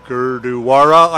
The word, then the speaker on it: Gurdwara: Yes, Kitchener-Waterloo, with one exception, the Sikh Gurdwara, I think is how you pronounce it, is just on past the western edge of Kitchener, but it's the only one.